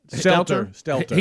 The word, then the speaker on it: Stelter: And then And then we have this this college freshman that's going to talk to CNN's, how do you say his name, Brian Stelter? Is it Stelter? – Stelter.